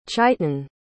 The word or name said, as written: chitan